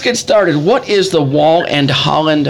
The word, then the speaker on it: Wahl-Holland: They're representing Wahl-Holland Home. Did I say that correctly? – You did.